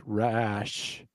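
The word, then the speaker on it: Racz: I think it's pronounced Rash. It's Hungarian. So for those of you who are listening and want to know why, they're like, why don't they know how to pronounce Rash or whatever, or race? It's spelled R-A-C as in Charles, Z as in zebra.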